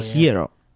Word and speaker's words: gyro: And so I always say all the pronunciations, and I say whenever they have a gyro place, I say hiro.